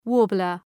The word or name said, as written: warbler